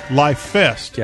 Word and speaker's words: LIFEST: Life Fest. – Yeah, so it's like Life Fest, but one word altogether, L-I-F-E-S-T.